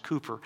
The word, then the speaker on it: Cowper: Well, one of my favorite hymn writers is Cooper, C-O-W-P-E-R, but it's pronounced Cooper.